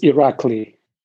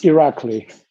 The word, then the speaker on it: Irakli: I pronounce my name as Irakli. Oh. Like Saddam Hussein from Iraq and Lee like Bruce Lee. So it's Irakli.